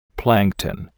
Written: plankton

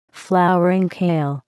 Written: flowering kale

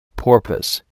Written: porpoise

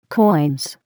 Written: coins

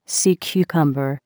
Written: sea cucumber

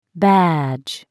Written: badge

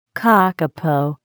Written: kakapo

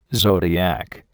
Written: zodiac

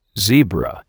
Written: zebra

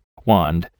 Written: wand

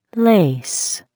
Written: lace

